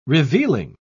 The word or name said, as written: revealing